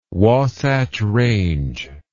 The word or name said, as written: Wasatch Range